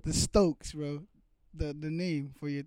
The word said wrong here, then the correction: Stocks: It's pronounced Stocks. – Stocks, sorry.